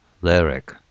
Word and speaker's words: lyric: Lyric。 L ですよ、最初は。 Lyr。次は R で、 Rick と発音間違ってたんですけど、実は Lear、 Lyric と。で、 Lear っていうのは耳に当たる ear ですね。耳、 ear の前に L をつける。